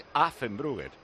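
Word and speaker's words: Affengruber: ¿Estás diciendo Effengruber? Es Affenbruger.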